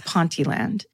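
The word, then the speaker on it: Ponteland: I've been given the pronunciation, I don't trust it. And I don't want to be attacked for it, but apparently it's Pontyland.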